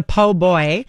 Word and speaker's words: po' boy: That's the po' boy. – Yes. – I'm not mispronouncing that. That's what it's called.